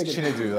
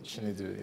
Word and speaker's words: Chinedu: It's Chinedu, like Xanadu. – Chinedu. – Chinedu, yeah.